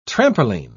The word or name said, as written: trampoline